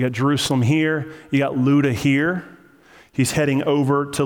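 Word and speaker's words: Lydda: Now, this is pronounced Luda. It looks weird. It looks like Lida. But the guy in the Strong's Concordance that pronounced it to me before the service said Luda. So I'm going to say Luda.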